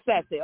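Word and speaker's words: Sachse: I am, y'all literally left Satche. Am I pronouncing it right? Satche, Texas? – Satche, yes. Yeah. – It is Satche.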